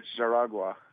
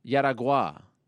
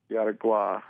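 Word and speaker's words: Jaraguá: I believe it's Jaragua. – Yeah, according to Vitor Belfort, it was Yaragua. – Yaragua. – You don't really pronounce the J.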